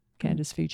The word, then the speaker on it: Candace Veach: And I believe I have a Facebook page, Candace Veach Healer.